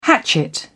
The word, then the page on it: hatchet: British English